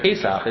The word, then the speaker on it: Pesaj: So their J is the closest analogy to our ch sound. So their Pesach is actually spelled P-E-S-A-J, and I just found that very amusing.